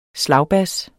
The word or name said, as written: slagbas